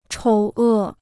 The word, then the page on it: 丑恶: chǒu è